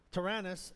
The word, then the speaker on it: Tyrannus: I have this new app on the, I found this new app on the computer, and it tells you how to say words. So I'm always looking up these words and phonetically putting them in there. And it's Tyrannus. So Tyrannus, and in the daily encounters in the house churches of Ephesus.